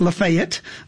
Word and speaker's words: Lafayette: We pronounce it Lafayette in Mississippi.